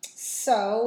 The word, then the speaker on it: Ceaux: So they're playing Friday night, thanks to Wendigo, and with support from a few other bands, Honor Among Thieves, So, which is pronounced C-E-A, which is spelled C-E-A-U-X, so I'm not sure it's pronounced So, but that's what it looks like.